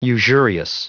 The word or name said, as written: usurious